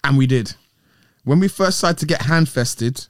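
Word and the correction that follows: handfasted: hand fasted, I'm saying it wrong, hand fasted, Chris proposed an agreement in which we'd be free to be sexual with other people during fair, but at no other time.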